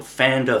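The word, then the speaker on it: Pfander: He has a website, a YouTube channel called Fander Films. It's P-F-A-N-D-E-R, Fander Films, with a silent P at the start.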